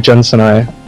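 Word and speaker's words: Jun Senoue: He's going to be performing, I guess, his Sonic Chill album that was recorded really badly or something with Jun Sanui. – Is that how you pronounce it? – Absolutely, that is how you pronounce it.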